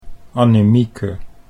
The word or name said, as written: Annemieke